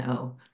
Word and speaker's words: Bonanno: So Kelly is just Kelly, and then Bonanno is Bonanno.